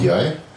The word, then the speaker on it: Ndiaye: Wie wird er eigentlich ausgesprochen? Ndiaye. Ndiaye? Ndiaye? Okay.